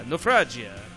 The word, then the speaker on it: Naufragia: They're like, well, I guess that's how you pronounce it. I was like, yeah, Naufragia. You got to be Italian for that one, I guess.